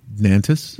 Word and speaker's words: Nantes: Nantes? N-A-N-T-E-S? How do you say that? – Nantes? – Nantes. Okay, Nantes, France.